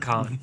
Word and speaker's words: pecan: Man, um, but make sure you don't eat pecan pie because that's that's pretty it's, close to it's pronounced pecan. Pecan.